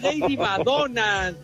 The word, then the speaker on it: Lady Madonna: Lady Mamona. – No, no. es Lady Madonna. – Ay, pronuncia bien, hombre.